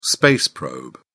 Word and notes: space probe: British English

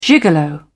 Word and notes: gigolo: British English

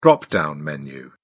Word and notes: drop-down menu: British English

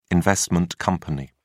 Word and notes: investment company: British English

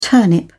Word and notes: turnip: British English

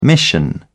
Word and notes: mission: British English

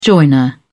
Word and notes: joiner: British English

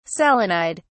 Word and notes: selenide: British English

